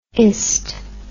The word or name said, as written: ist